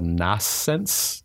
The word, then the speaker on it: Nascence: it's called Nascence, N-A-S-C-E-N-C-E, and that's the title screen.